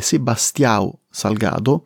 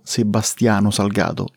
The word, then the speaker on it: Sebastião Salgado: Lui è brasiliano, il suo nome sarebbe Sebastião Salgado, ma per praticità lo chiameremo Sebastião Salgado. Italianizziamo il nome così ogni volta non mi devo correggere ed evito le papere.